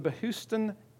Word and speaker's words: Behistun: This is called, correctly pronounced, the Behistun inscription.